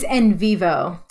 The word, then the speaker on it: Envivo: Is the founder of Envivo. Did I say that right? – It's Envivo. It's a nonsensical word.